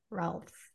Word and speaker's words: Rohlfs: So friends, if you are struggling, your next right step is to pick up Finding Freedom by Melissa Rohlfs.